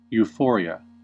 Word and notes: euphoria: American English

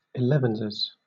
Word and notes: elevenses: British English

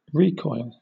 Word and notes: recoil: British English; noun

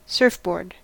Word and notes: surfboard: American English

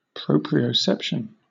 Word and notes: proprioception: British English

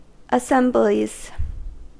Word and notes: assemblies: American English

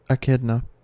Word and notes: echidna: American English

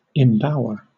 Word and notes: embower: British English

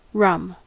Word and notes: rum: American English

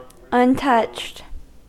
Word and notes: untouched: American English

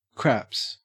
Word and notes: craps: Australian English

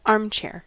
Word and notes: armchair: American English

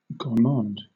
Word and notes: gourmand: British English

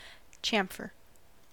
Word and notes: chamfer: American English

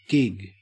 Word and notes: gig: Australian English

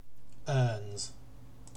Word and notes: earns: British English

